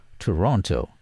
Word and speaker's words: Toronto: You see, as much as a city is spelt T-O-R-O-N-T-O, the T after the N is silent. You know, some newscasters and foreigners pronounce it as Toronto, but the locals pronounce it as Toronto.